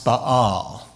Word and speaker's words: Baal: So, in other words, the prophets of Baal, pronounced Baal, who was the pagan's god of success and money.